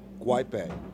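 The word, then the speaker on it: Guaipe: Guapi Maykol. – Okay. – Maykol Guapi. – Somewhat close. It's actually Michael Guaype. – Not close. – In my defense, his name is spelled M-A-Y-C-K-O-L.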